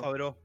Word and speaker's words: Favreau: John Favreau. – John Favreau.